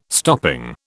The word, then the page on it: stopping: British English